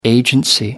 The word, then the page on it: agency: American English